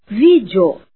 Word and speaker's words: vídeo: Exemplo, vídeo. O E é semivogal, pois é pronunciado como I.